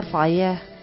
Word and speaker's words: foyer: Sala foyer. Bueno. Que bueno, seguramente. – Sin R al final porque es muy en francés, así que no se debe, no, se debe decir esa R.